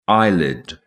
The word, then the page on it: eyelid: British English